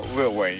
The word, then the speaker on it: Lil Wayne: Little Wayne. – No, Lil Wayne. You got to say it right, Jeremy.